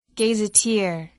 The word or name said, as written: gazetteer